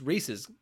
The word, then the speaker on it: Reese's: It's Reese's because it rhymes with pieces.